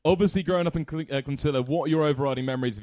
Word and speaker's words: Clonsilla: He just said Clonsilla, and like that's weird. Like you said it wrong, but it's Clonsilla.